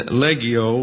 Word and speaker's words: legio: And legio would be the correct pronunciation. That's a legion.